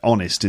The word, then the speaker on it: honest: Honest is a silent H, but certainly has and happened.